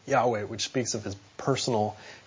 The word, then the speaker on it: Yahweh: But it's the name Yahweh, or Jehovah, if you want to pronounce it that way.